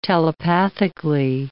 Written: telepathically

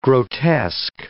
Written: grotesque

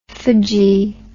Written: phagy

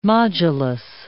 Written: modulus

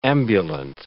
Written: ambulant

